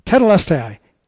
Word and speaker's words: Tetelestai: Tetelestai.